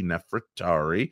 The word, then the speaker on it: Nefertari: Yes, I'm saying that right. Not Nefertiti, Nefertari.